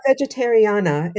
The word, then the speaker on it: Vegetariana: It's Vegetariana. It's like sort of like it rhymes with Americana.